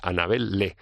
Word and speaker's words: Annabel Lee: digáis Annabel Lee, no, hay que evitar la pronunciación british y se pronuncia tal como se lee, esta Annabel Lee.